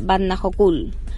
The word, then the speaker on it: Vatnajökull: Comenzamos nuestro quinto día del road trip por Islandia en Skaftafell, en el Parque Nacional de Vatnajökull.